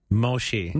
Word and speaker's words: Moshe: Moshe. – Moshe. – Just the fact that you're pronouncing it as Moshe tells me something. – Well, that's Hebrew for Moses.